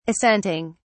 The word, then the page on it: assenting: British English